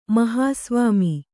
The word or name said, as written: ಮಹಾಸ್ವಾಮಿ